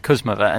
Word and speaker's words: Kuzmova: Yes, well, that was because it came to my attention during the match that all this time I've been pronouncing it incorrectly, as Mariana Veljevic, the umpire, was saying Kuzmova, and she is always correct.